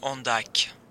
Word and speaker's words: Andac: It's Richard Andac. – Can you spell that, please? – A-N-D-A-C. – So that's A-N-D-A-C.